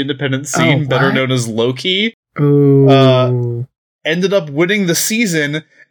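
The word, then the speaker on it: Low Ki: So his name is Loki. It's spelled LOW-KI. – Okay. – So like it's meant to invoke Loki, but it's not.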